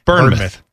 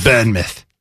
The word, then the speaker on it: Bournemouth: Bournemouth. – Come on. Say it like you're from there. – Bournemouth. – Of course, it looks like Jason Bourne's mouth is how it's spelled, but it's Bournemouth.